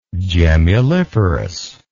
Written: gemmuliferous

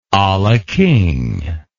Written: a la king

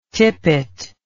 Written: Tippett

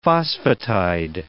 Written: phosphatide

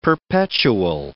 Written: perpetual

